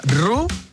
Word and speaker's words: ro: Me dice Mauricio que se pronuncia Ru, la o en Noruega. Suena como U.